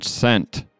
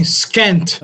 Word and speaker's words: scent: In the word scent, S-C-E-N-T, which letter is silent, the S or the C? – I think it's C. – Yeah, I mean, you could argue either way. – There's no wrong answer, so. – I passed English class, so I believe it's the letter C. – Okay. All right. – I like it. – Or else you'd be saying skent.